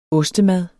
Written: ostemad

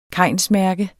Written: kainsmærke

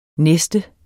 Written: NESTET

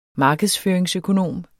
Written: markedsføringsøkonom

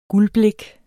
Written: guldblik

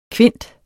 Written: kvint

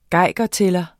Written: geigertæller

